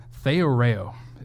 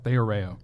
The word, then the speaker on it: theoreo: And this second Greek word is theoreo, is how you say this word, theoreo.